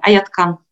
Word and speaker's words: Айеткан: Да, вы все правильно, и правильное ударение. Айеткан.